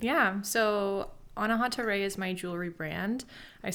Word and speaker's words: Anahata Ray: Anahata Ray.